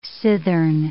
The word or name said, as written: cithern